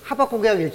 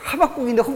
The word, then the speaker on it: 하박국: 자, 이제 그 다음에 등장하는 사람이 이제 하박국 선지자인데 시골에 계신 권사님들은 이거를 호박국이라고 읽지 않고 어떻게 읽어요? 호박국이라고 읽어요. 호박국. 앞니가 빠진 권사님이 자꾸 하박국이라니까, 앞니가 빠지니까 호박국, 호박국, 하박국인데 호박국인 거야.